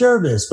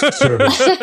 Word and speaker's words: Gervase: So I always say it's like nervous, rhymes with nervous. Mm-hmm. It's easy to remember. So people go, oh, Nervous Gervase.